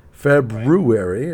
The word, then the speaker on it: February: this there's this random R thrown in there that I, uh, that nobody well nobody from this end of the country pronounces. It's February apparently.